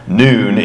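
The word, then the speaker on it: nun: And it's pronounced noon in the Arabic alphabet.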